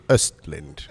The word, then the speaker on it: Östlund: It is. It's the English version of my name. It's actually Östlund.